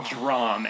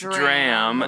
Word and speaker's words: dram: Is it pronounced dram or drom? – What? – Because Curtis said drom, and I went, no. – It's dram. Okay.